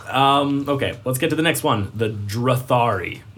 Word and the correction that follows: Drathri: Nope, I said that wrong. The Drathri.